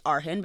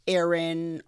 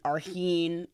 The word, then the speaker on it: Arhin: and it's Arhin, but people often say Aaron, Arhin. I'm like, it's phonetic.